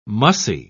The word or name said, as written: mussy